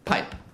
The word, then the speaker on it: Pijp: How do you say it? Don't fuck with me. P I J P pipe.